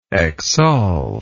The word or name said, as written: exsolve